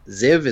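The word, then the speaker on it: Ziev: Ziv is how it's pronounced, but it's Z-I. It's J-A-C-N. No, J-A-C-E-N. Z-I-E-V.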